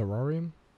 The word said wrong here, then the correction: Saurion: It is Saurion. Saurion.